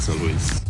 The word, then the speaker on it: Saarlouis: Und spricht man das übrigens aus, nach Saarlouis.